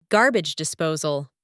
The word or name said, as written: garbage disposal